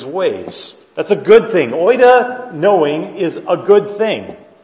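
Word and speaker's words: oida (οἶδα): oida.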